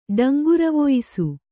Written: ಡಂಗುರವೊಯಿಸು

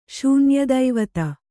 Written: ಶೂನ್ಯದೈವತ

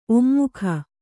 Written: ಒಮ್ಮೊಗ

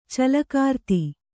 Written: ಚಲ್ಲಕಾರ್ತಿ

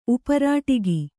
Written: ಉಪರಾಟಿಗಿ

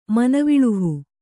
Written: ಮನವಿಳುಹು

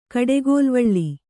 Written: ಕಡೆಗೋಲ್ವಳ್ಳಿ

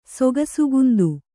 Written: ಸೊಗಸುಗುಂದು

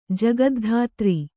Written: ಜಗದ್ಧಾತ್ರಿ

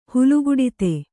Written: ಹುಲುಗುಡಿತೆ

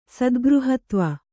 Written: ಸದ್ಗøಹತ್ವ

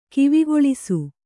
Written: ಕಿವಿಗೇಳಿಸು

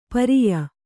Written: ಪರ್ಯ

